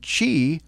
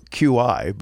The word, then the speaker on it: Qi: From Skosh, Magic Mount Pro Charge 5 for MagSafe and Qi, or what some people call QI, but it's Chinese and it's pronounced Qi, enabled phones.